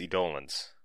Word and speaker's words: Eidolons: I think it is Eidolons.